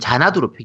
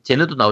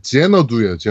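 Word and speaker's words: Xanadu: 뭐라고 발음돼 있어요? 발음 기호상? 도원경이고 제 제너두예요. 제너두. – 아 제너두예요. 네. 아 그렇군요. 제너두라고 합니다. 그럼 제너두라고 읽겠습니다.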